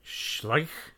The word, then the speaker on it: Schleich: I look at the bottom and they say like 2006 or 2009 and they're by the company, I'm going to pronounce this wrong because it's going to sound Serbian, but it's spelled like Sreć, but not S-R. It's S-C-H-L-E-I-C-H. – Yeah, we've talked about them before. – Yeah, how do you pronounce it? – Schleich.